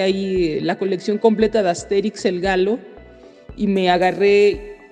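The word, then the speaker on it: Astérix: Asterix, no sé, yo siempre lo he pronunciado como Asterix, porque lleva acento en la E, en la traducción española, aunque he escuchado mucha gente que le dice Asterix y Obelix. Bueno, es Asterix, según yo.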